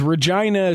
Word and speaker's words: Regina: He says, Regina is pronounced exactly the way that makes you giggle.